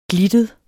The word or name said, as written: glittet